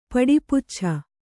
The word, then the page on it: ಪಡಿಪಚ್ಛ: Kannada; paḍi paccha